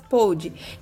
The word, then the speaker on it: Pod: o arroba Eufóricas Pod, que é a palavra podcast, só que só usa o P-O-D e o D é mudo.